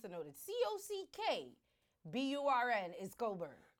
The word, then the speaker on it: Cockburn: y'all know Coburn is spelled C-O-C-K-B-U-R-N. Mm-hmm. And I didn't know that C-O-C-K-B-U-R-N was Coburn. – The C-K is silent.